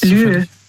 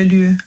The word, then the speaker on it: lieu: Lieu. – Lü? – Also Lü, das schreibt man also L-I-E und dann U. Le Lü.